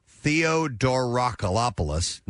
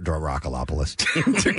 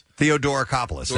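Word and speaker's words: Theodoracopulos: Now, the journalist who penned the piece, which was published in Spectator magazine on Friday, is Takei Theodorakopoulos, not Dorakopoulos. look at this, Casey. – All right. – See if you can pronounce it. – Takei Theodorakopoulos. – Theodorakopoulos, thank you.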